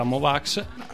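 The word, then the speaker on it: Mo' Wax: il genere musicale in linea di massima della Mowox. – Si pronuncia Mowox, tra l'altro.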